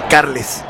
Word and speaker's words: Carles: Y se pronuncia Carles.